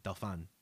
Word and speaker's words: Dauphin: Movie, who plays the Dauphin or the Dauphin. I forgot how they pronounce Dauphin. – It. Dauphin. – Dauphin.